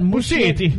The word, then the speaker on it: Muschietti: Does he pronounce his name Machete? – King? – Machete? – Yes, Machete. – Actually, I thought Muschietti. Muschietti. – Muschietti. – Muschietti. That's what it sounds like.